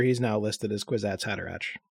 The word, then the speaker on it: Haderach: I have always had that CH as a hard sound. – That makes way more sense. Yeah. Yeah. Huh. Haderach.